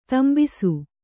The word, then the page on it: ದಂಬಿಸು: kannada